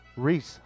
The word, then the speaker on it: Rhys: First base from the Phillies, Reese Hoskins. And Reese is spelled R-H-Y-S.